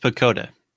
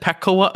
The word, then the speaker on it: PECOTA: So what I want to start with here is say that it's pronounced Pecota. – Oh, it's Pecota. I said Petcoa again? – Yes.